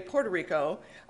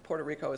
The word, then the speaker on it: Puerto Rico: Puerto Rico as well. So just so you know, I can say it properly.